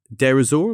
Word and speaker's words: Deir ez-Zor: So this incident happens in February 2018 in Deir ez-Zor.